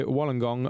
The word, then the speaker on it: Wollongong: In the middle of this season, I emigrated to Wollongong, Australia, a city just south of Sydney. – Good pronunciation there.